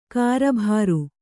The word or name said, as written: ಕರ್ಬುರ